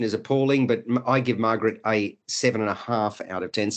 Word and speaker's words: Margrete: Yes, good film, Margrethe. As it's pronounced, 7 out of 10 from me.